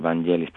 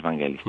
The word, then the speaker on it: Vangelis: vamos a decirlo bien, la pronunciación es así porque, bueno, es un nombre griego. Todos tendemos a decir Vangelis porque leemos Vangelis y parece que es en inglés, pero bueno, es Vangelis. Pues el músico este, bueno, guarda con mucho celo su vida privada y ni siquiera tiene página web oficial.